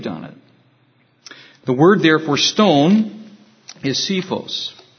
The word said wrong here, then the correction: Cephas: and it simply means a pebble. I think it's cephas, actually, the way you pronounce it.